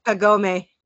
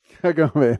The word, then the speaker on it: Kagome: Kagome. – Kagome.